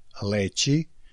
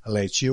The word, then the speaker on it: leći: Prvi je svršeni glagol leći, s kratkouzlaznim akcentom na prvom slogu, čije je primarno značenje zauzeti vodoravan položaj, opružiti se, ispružiti se, poći na počinak, na spavanje, na primjer, leći u krevet.